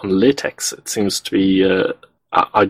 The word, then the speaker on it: LaTeX: This is why the people that use it pronounce it LaTeX.